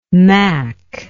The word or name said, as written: mack